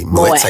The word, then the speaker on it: Moët: In a moué. Am I pronouncing this correctly? Moët, I think.